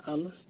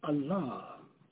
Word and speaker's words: Allah: It's not Allah, it's Allah. And the second syllable should be a little bit elongated. Allah.